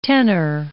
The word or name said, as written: tenour